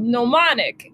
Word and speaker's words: Gnomonic: We've got a little teamwork on it, but the game I'm working on is called Gnomonic, and it's a silent G N O M O N I C, and uh, if you like symmetry, well, you're gonna hate our logo because I'm the one who made it not symmetrical.